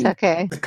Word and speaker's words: Takei: Takei.